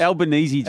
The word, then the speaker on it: Albanese: And for me, Purple, just from afar looking in, I'm a Albazese. Old Albo, in he comes. – Albanese. – Albazese, and in he comes. And I tell you, he's getting the name wrong.